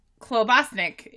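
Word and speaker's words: klobasník: Klobaspnik is a meat-filled pastry, is what a meat-filled pastry is known as in Czech. She wrote it correctly. I said it wrong.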